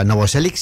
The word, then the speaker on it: Novoselic: Pero ya Novoselic, ¿no? Lo he dicho bien, ¿no? – Es como motor. – O sea, Novoselic sí, el vale.